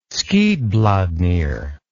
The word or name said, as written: skidbladnir